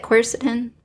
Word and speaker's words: quercetin: And I think the elderberry in it, with it has a natural, what's that, quercetin? – Yep.